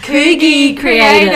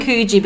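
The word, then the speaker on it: Koogii Kreative: Coogie Creative. – I kept saying Coogee, but guys, don't get Coogee in your head. It's definitely not Coogee. Forget about Coogee.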